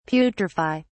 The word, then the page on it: putrify: British English